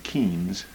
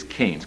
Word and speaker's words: Keynes: You all have heard of John Maynard Keynes, although I find that most people pronounce his name Keynes. It is Keynes, K-E-Y-N-E-S, the British economist who in the 1930s published a book called The General Theory of Employment, Interest, and Money.